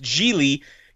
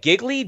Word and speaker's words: Gigli: Yeah, but like, then you see it spelled, and it is one of these things where no matter how many times you tell a person it's pronounced Gigli, you look at it and you're like, Giggly? Jiggly?